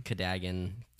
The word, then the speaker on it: Cadogan: Not, I pronounced it Cadogan. It's Cadogan. – Cadogan. – Like a toboggan.